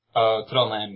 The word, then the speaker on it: Trondheim: It's Trondheim? – Trondheim, yeah.